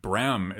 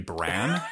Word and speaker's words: Bram: So, even less known fact, Bram is the incorrect way to pronounce it. It's actually Bran, like the cereal.